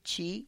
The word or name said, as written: txi